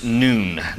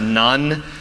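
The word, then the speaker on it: nun: The last section we'll look at is here in verse 105, and the letter that marks this section is pronounced noon, not none. That is something different that some of you grew up with who threw rulers across your knuckles. This word is noon.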